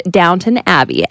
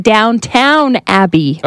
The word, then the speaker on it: Downton Abbey: For example, this person says, I finally started to watch Downton Abbey and realized I've always been saying Downtown Abbey.